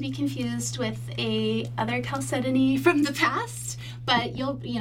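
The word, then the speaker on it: Chalcedony: Calcedony. – Calcedony. – Calcedony. – Write that down, Pancho. – Yeah, the H is silent.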